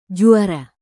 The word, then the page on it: juara: Indonesian